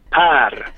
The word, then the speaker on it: Per: Per, welcome to the show, and can you say your name in the Swedish way so people know your actual name? – Per.